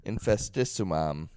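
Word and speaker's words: Infestissumam: So I had a buddy that when Infestafestacism came out, it's their second album. I can't fucking say it. How would you say it? – Infestissimum. – Yeah, what he said came out.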